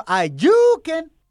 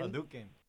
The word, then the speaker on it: Hadouken: Wait, is it Hadouken or Ayuken? – I thought it was Hadouken. – Hadouken. – It's Hadouken? Uh-huh.